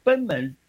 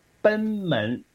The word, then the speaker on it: 贲门: 然后再下来就是食道跟贲门，叫食道跟胃之间的这一个，也是有一个很重要的括约肌的地方。那这个地方呢，称之为贲门。那有的人呢，会把那个贲门呢念成贲门，其实不是贲门，念成贲，贲是奔放的意思，是能够有向前冲的意思啊。